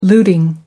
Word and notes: looting: American English

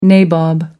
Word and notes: nabob: American English

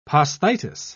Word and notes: posthitis: British English

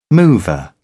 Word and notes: mover: British English

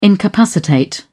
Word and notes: incapacitate: British English

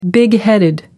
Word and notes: bigheaded: American English

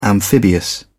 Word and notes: amphibious: British English

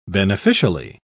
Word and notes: beneficially: British English

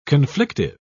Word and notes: conflictive: British English

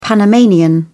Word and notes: panamanian: British English

